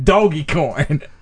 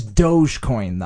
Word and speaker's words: Dogecoin: Or you might have said doggy coin at first. It is Dogecoin, though.